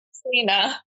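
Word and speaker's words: Caitríona: It's probably Katrina. – I go with that. – We'll go Katrina Balfe.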